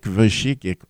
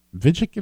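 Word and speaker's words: Vujicic: His name is Vic Vashikic or something. I can't pronounce it. – Yeah, that's close. I like it. – It's V-U-J-I-C-I-C. Vigic is it?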